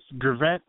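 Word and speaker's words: Geveret: Gevret.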